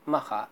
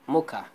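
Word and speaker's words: Mokha: Okay, so we are Arabic people, we pronounce it Makha, but like to non-native speakers, non-Arabic native speakers, they call it Mocha.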